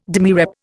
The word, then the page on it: demirep: Indian